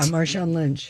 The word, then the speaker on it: Marshawn Lynch: Yeah, Marshawn Lynch.